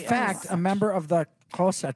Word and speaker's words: Xhosa: The language is Xhosa. That's how they say it, Xhosa.